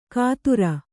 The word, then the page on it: ಕಾತುರ: kātura